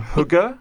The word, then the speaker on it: hygge: and I'm going to totally get this pronunciation wrong, hygge. – That's correct, yeah.